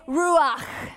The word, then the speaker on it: Ruach: In Hebrew, it's Ruach. You see, I actually spit. You'd be so proud. See? That's how you pronounce it, right? If you're doing it properly, Miss Roberta will tell you, you have to pretend you have a hairball or popcorn stuck in the back of your throat, right?